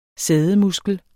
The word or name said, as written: sædemuskel